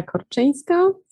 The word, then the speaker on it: Korczynska: Yeah, so I'm Emilia Korczynska from User Pilot, and originally from Poland, hence the difficult last name, Yeah, me too.